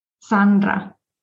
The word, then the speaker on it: Sandra: Sandra.